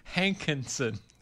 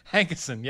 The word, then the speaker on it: Hankison: Hankinson. – Hank. – Hankison.